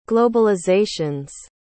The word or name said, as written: globalizations